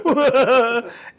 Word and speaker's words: Mijo: Basically, in Spanish, we say it right. It's Mijo, which means son.